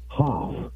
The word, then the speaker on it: Hopf: So I was hoping to get a call from Clint Abound's new OTB director, Frank H-O-P-F, Hoff. I guess that's how you pronounce the new director of OTBs at Colonial Downs.